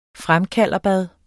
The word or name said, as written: fremkalderbad